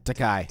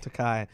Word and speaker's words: Takei: Takei. – Takei and all everything going on.